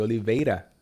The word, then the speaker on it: Oliveira: Um, Chocolate Yoddah, I think you're the first person that pronounces my name correctly, Oliveira.